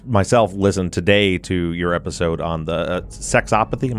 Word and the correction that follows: Sexsomnia: Am I saying that right? – Sexsomnia.